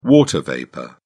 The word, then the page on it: water vapour: British English